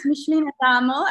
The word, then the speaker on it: Michelene Adamo: Yes, Michelene Adamo. At the moment, it's Michelene Adamo Reynolds.